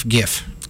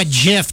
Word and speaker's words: GIF: What about GIF? – Oh, that's always been GIF. – Hargy. – GIF. No, GIF. – GIF. – Hargy. – Yeah, not a J.